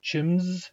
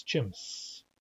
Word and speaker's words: chimps: Those of you with English accents may be tempted to pronounce it chims as if it ended with a Z, but it does not. It's chimps. S is an S is an S.